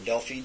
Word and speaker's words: Delphi: It's named after a place in Greece because all of their knowledgeable people used to be from Delphi, or Delphi, depending on how you pronounce it.